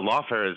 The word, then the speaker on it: lawfare: No, lawfare is, that's how you say it.